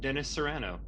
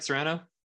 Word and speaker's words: Serrano: This episode we'll be interviewing horror and thriller writer and filmmaker Dennis Serrano. Am I pronouncing that right, Serrano? – Oh, you got it, yep.